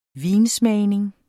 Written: vinsmagning

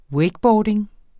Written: wakeboarding

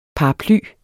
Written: paraply

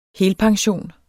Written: helpension

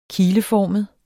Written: kileformet

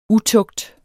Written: Utugten

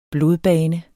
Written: blodbane

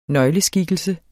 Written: nøgleskikkelse

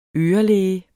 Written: ørelæge

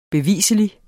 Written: BEVISLIGT

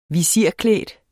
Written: visirklædt

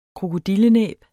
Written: krokodillenæb

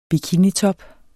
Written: bikinitop

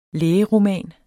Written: lægeroman

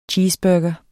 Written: cheeseburger

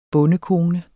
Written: bondekone

